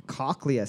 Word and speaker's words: cochlea: The cochlea, C-H-L-E-A, is a bone in the ear. Its name coming from the Greek word for what creature? I think I'm pronouncing that right. – Are pronouncing it right.